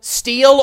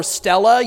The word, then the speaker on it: Stele: I mention here the Tel Dan Stele or Stella. You can pronounce it either way.